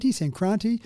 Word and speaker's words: Sankranti: So, and again, the pronunciation, I'll go with yours one, the Makar Sankranti sounds pretty good. Sankranti.